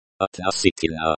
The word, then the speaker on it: относительно: The stress in относительно. Относительно falls on the third syllable.